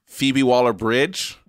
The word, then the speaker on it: Phoebe Waller-Bridge: Phoebe Waller-Bridge. – Genius. – Is that how you say her name? – Yes.